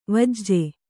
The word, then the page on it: ವೆಜ್ಜ: Kannada